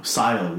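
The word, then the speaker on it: Cyle: We're not going to show love to Kyle Larin? – No, Sile Larin. I'm not going to pronounce CY as Kyle's son. That's incorrect.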